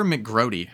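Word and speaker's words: McGroarty: And Rutger McGrout, how do you say his last name? McGrouty? – Rutger McGrouty. – McGrouty. Yeah, I mean, he was USA's captain.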